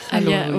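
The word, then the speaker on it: khalwa: tu peux parler de la halwa, je sais pas si je prononce bien. – C'est ça, la halwa.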